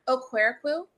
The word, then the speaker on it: Okwerekwu: Okwerekwu.